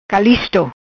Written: Calisto

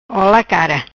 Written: Cara ola

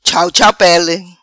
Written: Chau Chau Pele

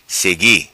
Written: Segui

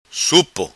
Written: Supo

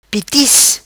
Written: Pitis

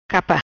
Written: Capa

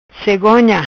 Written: Cegonha

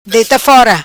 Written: Deita fora

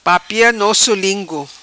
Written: Papia Nosso Lingu

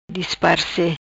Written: Disparece

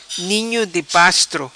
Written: ninho di pastro

